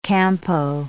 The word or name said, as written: campo